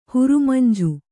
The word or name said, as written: ಹುರಮಂಜಿ